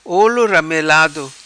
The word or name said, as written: Olo Ramelado